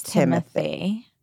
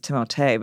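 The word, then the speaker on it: Timothée: Is it Timothee or is it It's Timothee. – He said in an interview recently, he's like, the proper pronunciation is Timothee, but he's like, I'm never going to correct anybody on it because I don't want to sound like a dick.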